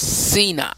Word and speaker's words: Sinach: It is originated by, I think her name is pronounced Sina, S-I-N-A-C-H.